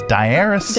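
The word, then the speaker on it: diaeresis: A vowel with two dots is known as a diaresis. – I had that, and then I took a pill for it. And it's diaresis.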